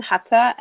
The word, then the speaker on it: Huter: You can find me at Carmen Hutter. It's spelled like Hunter, but there's no N in my last name. So it's H-U-T-E-R.